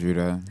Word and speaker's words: Rudo: My name is Rudo. – Hello. – Hello, darling. – Hello, darling. – You can find me on SoundCloud. Pronunciation RU-DOE, or you can just spell out Rudo Reid.